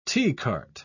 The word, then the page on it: teacart: British English